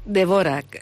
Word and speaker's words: Dvořák: Dvořák. – Perquè jo fins que aquí no vaig aprendre com es pronunciava sempre el Dvořák. Sí, no, Dvořák.